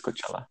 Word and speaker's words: Coachella: It's not Brochella. It's Coachella. Anyways, it says Coachella herps spikes record 250 cases a day near festival.